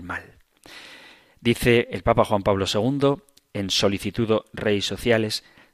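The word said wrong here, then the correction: Sollicitudo Rei Socialis: Socialis, siempre lo digo mal, Solicitudo Rei Socialis,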